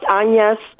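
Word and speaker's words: Agnès: It's spelled just like it is in English, Agnes, but it's pronounced Agnes Theodore.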